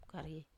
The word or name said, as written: karie